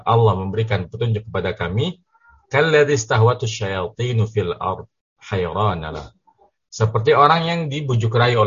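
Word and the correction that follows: fil ardi hayrana (فِي الْأَرْضِ حَيْرَانَ): Oh, ini tadi bacanya ini ya. Salah motong ya. Jadi harusnya fil ardi hayrana gitu kan.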